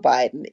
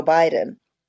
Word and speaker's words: Biden: Joe Biden, remember, put the emphasis on Biden. It's not Joe Biden. It's Joe Biden. We really want you to pronounce those two syllables, Biden, Joe Biden.